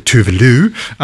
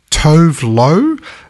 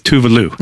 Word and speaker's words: Tove Lo: It's obviously Tove Lo, but looking at how it's spelled, you could go with Tove Lo.